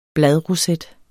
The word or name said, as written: bladroset